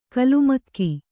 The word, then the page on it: ಕಲುಮಕ್ಕಿ: kalumakki